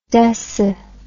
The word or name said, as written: deci